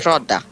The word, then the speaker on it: Rodda: Allora, il titolo è, si pronuncia Rodda, cioè lei.